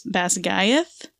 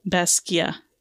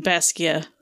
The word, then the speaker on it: Basgiath: This is actually pronounced Basgia, and the TH at the end is silent. So we'll be saying Basgia.